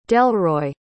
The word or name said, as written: delroy